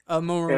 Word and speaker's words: Moroni: Moroni.